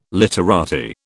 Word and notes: literati: British English

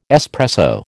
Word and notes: espresso: American English

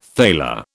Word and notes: thaler: British English

